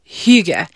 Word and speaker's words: hygge: It's, if I were to, I checked with people how to pronounce this. – Please. – Certain people. It's hygge.